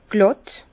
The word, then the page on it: glàtt: Bas-Rhin; Herrlisheim